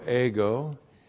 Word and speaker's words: ego: E-G, long O, ego.